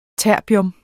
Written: terbium

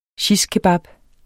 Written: shishkebab

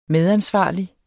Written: medansvarlig

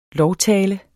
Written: LOVTALER

